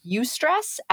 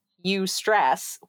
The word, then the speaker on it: eustress: It's not spelled at all the way that it sounds. It's pronounced eustress, as in like eustress, which is hilarious, but it's spelled with an A.